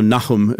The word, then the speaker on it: Nahum: But the point is that just two books over, you have the Book of Nahum, and Nahum, also Nahum, as it's pronounced in Hebrew.